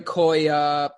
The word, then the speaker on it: Koya: How do you say that? – Koya, Drinks Koya, which looks phenomenal.